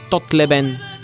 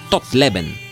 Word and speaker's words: Тотлебен: Но даже и тези хора му слагат грешно ударението, защото генерала се казва не Тотлебен, а Тотлебен.